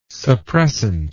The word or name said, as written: suppressant